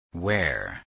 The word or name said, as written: wear